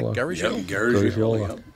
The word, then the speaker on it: Garagiola: Major League journeyman Joe Garagiola. Did I say that right? – Garagiola.